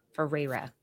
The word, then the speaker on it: Ferreira: Ferreira. You have to really roll it.